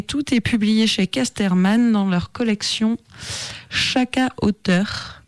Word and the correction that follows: Sakka: Donc, Saka, pardon. Saka Auteur, un peu comme un sac à hauteur, sauf que ça s'écrit S-A-K-K-A.